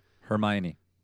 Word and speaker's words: Hermione: I wanted to ask you guys, how do you pronounce that actress's first name? Her last name is Corfield. – Hermione. – Hermione. Okay, that's the Harry Potter name.